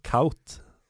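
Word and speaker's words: Kuyt: Når Dirk Coit faktisk skal uttales Kaut med den UY-lyden, hvorfor er det ikke da Det Braune? – Ja, godt poeng!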